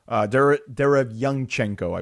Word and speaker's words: Derevyanchenko: Derevyanchenko, I believe, is the way you pronounce his name.